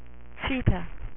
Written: Cheetah

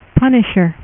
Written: Punisher

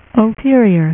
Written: ultron

